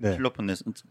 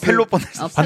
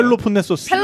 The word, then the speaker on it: 펠로폰네소스: 스파르타가 있는 그 필로폰네소스. – 네. – 필로폰네소스. – 펠로폰네소스.